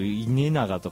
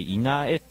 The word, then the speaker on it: 稲江: 稲江、ね、僕稲永とかだと思ったんですけどこれ稲江って読むんですね、稲穂の稲に永久の A で稲江だそうでございますが、稲江スポーツセンターで 14 時から名古屋オーシャンズとの開幕戦を迎えることになります。